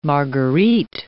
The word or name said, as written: marguerite